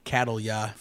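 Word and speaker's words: Cattleya: Catalya for people who don't pronounce things. – Perfect. – Catalya. – I was putting an extra syllable in there. Catalya. – The wrong syllable on the wrong emphasis.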